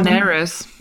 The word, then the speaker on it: Daenerys: Also hier zum Beispiel, jetzt weiß ich gar nicht, wie spricht man die aus? Daenerys war es im Hörbuch, Daenerys war es in der wunderbaren. – Daenerys. – Ja, genau. Ähm,